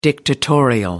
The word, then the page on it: dictatorial: American English